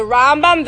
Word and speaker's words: Rambam: I said, the Rambam, the Ramban.